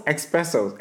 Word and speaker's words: espresso: I used to say espresso because I guess you think of express and oh, when the correct word, there's no X in there. The correct. Correct to the list? Correct way of pronouncing the word is actually espresso.